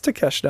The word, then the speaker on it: Takeshita: Takeshita was great. Danielson, that's like a dream match. – You got the name right. You're usually terrible with names. – It's Takeshita.